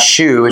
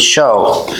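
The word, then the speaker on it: shew: Behold, I show, that's S-H-E-W, it's pronounced show. Look it up in the dictionary. It's not shoe, it's show. It's just one of those old English words.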